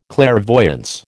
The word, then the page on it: clairvoyance: American English